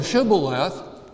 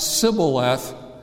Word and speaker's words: Shibboleth: But on the west side of the Jordan, they had a slightly different version of the Hebrew language, and they could not make the sh sound. They could only make a s sound. So Shibboleth, as they pronounced it in the Transjordanian area, came out Sibboleth every time.